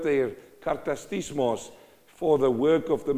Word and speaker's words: katartismos: The word for this phrase here in Greek is the word katartismos. I know I'm going really deep into the Greek, but hang in there. Is this interesting? He uses the word katartismos. Let me say it right. Katartismos.